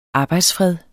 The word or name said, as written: arbejdsfred